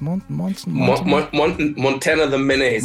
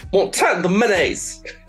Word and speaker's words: Montana the Menace: Montana the Menace. – Montana, sorry, ich habe das hier fünfmal falsch gesagt. Sorry, Montana the Menace. – Montana the Menace.